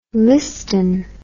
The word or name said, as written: Liston